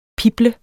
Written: pible

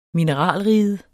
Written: mineralriget

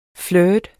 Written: flirt

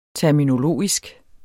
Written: terminologisk

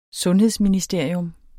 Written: sundhedsministerium

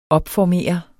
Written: opformere

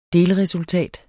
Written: delresultat

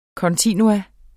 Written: kontinuum

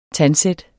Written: tandsæt

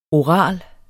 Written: oral

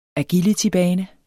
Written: agilitybane

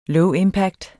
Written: low impact

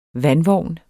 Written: vandvogn

